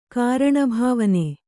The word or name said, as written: ಕಾರಣಭಾವನೆ